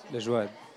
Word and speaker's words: Layoued: Est-ce que c'est juste en prononciation Layoued? – Layoued. – Ah ouais. OK. Layoued.